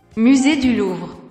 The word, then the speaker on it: Musée du Louvre: Musée du Louvre.